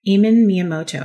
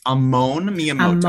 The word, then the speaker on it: Amon Miyamoto: Eamon Miyamoto, am I saying that correctly? – It's Amon Miyamoto.